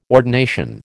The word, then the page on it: ordination: American English